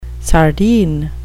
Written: sardine

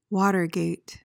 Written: watergate

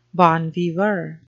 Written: bon viveur

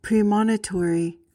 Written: premonitory

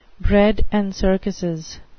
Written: bread and circuses

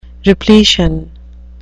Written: repletion

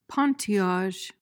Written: pointillage

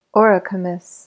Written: auricomous

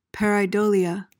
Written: pareidolia

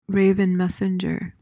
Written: raven messenger

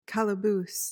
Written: calaboose

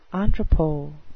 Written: entrepot

